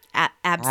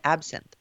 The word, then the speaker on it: Absinthe: Absinthe. I'm always going to say that wrong. How does it pronounce? – Absinthe. – Absinthe. There you go. – Yeah, you've got it. Absinthe.